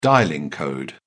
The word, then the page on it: dialling code: British English